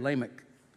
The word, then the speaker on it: Lamech: Lamech. – Very good, Lamech.